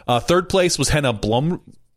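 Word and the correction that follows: Henna Blomroos: Wait, let's say it right. Henna Bloomroos. Bloomroos. That's what we're going with.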